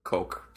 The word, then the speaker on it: Coq: Coq. C-O-Q. Coq de la Marche. – Pronounced Coq, duh.